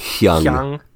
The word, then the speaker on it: Jung: The MBTI is based on a conceptual theory proposed by Swiss psychiatrist Carl Jung, or Jung. I don't know if that's however you pronounce it. – It's Jung. – Is it Jung? – It's Jung. – Jung.